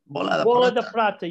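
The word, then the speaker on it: Bola de Prata: Did I say it right? Bola da Prata. Is that right? – Bola da Prata. Yes.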